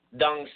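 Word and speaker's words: Tung: Dung style. It's in Roman letters. It's spelled T as in Tom, U N G, but that T in Chinese is pronounced like a soft D.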